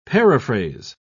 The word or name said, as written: paraphrase